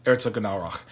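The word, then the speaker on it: Herzogenaurach: It's Erzgenaurach, which it's usually shortened to just Erzo. Herzo. – Herzo. – H-E-R-Z-O. No, don't say it like an Italian.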